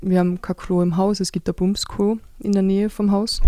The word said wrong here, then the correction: Plumpsklo: Ein Plumpsklo, genau so spricht man das aus.